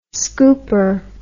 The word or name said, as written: scooper